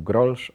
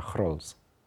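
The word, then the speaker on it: Grolsch: bo wszyscy mówią grolsz a się czyta chrols.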